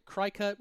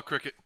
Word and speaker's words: Cricut: Cricut? Cri-Cut? One of those vinyl cutters. – Oh, Cricut.